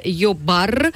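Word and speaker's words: jobbar: Потому что действительно йобар это работа. – Джоб, да, по-английски, оно произносится йо.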